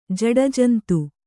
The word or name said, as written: ಜಡಜಂತು